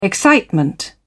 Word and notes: excitement: British English

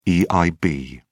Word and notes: eib: British English

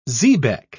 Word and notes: xebec: British English

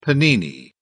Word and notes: panini: British English

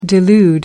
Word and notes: delude: American English

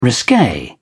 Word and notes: risque: British English

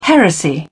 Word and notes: heresy: British English

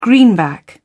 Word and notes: greenback: British English

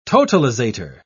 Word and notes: totalisator: British English

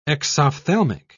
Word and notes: exophthalmic: British English

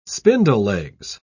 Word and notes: spindlelegs: British English

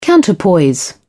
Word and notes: counterpoise: British English

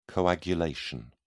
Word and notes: coagulation: British English

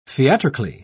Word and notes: theatrically: British English

